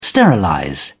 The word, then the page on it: sterilize: British English